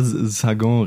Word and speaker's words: Sagan: Bonjour Tristesse von François Sargent reden. Jetzt habe ich es falsch gesagt. – Ne? Das ist falsch gesagt. Sollen wir mal? Ja. Franz. wir wollen. Wir wollen über Bonjour Tristesse von François Sagan reden. War das jetzt richtig? Ich, ich weiß, François Sagan.